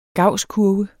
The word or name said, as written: gausskurve